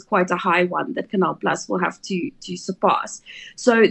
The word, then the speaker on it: Canal Plus: Currently, what, trading sort of low 90s on that 105 offer from Canal Plus, I was told is how you pronounce it.